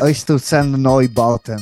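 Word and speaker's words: Einstürzende Neubauten: Einsturzende Neubauten, is that right? – Einsturzende Neubauten.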